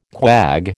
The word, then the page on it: quag: American English